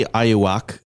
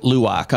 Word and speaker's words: Luwak: It's called Kopi Ayuwak. – Mm-hmm. Perfect pronunciation. – Thank you. – Yeah. Are you fluent? – Luwak.